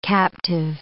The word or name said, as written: captive